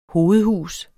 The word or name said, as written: hovedhus